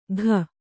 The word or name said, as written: ಘ